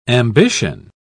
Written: ambition

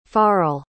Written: farl

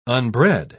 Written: unbred

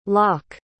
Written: locke

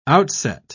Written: outset

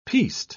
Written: piste

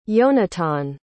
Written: yonatan

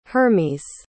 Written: Hermes

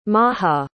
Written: mahah

